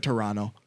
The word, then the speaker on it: Toronto: That's how Canadians say it. Toronto. – Toronto? – Yeah, you live in Toronto. – It's not Toronto? – No, Toronto.